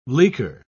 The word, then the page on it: leaker: British English